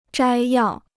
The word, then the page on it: 摘要: zhāi yào